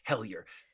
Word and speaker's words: Hellier: Sorry, I'm saying it wrong. It's Hellier. H-E-L-L-I-E-R. – But pronounced H-E-L-L. – Jack has confirmed is Hellier. – H-E-L-L-Y-E-R.